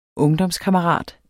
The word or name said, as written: ungdomskammerat